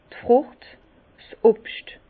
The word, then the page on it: d' Frùcht, 's Obscht: Bas Rhin; Herrlisheim